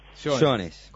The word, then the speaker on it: Jones: Muy bien, Cecilia, ¿Jones o Jones? – Jones. – Como Roberto. – Perfecto, como Roberto.